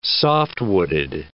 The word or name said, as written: soft-wooded